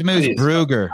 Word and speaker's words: Moosbrugger: Because I'm gonna give me Moosbrugger. – I think it's Moosbrugger. – Yeah, like, you don't say her name right.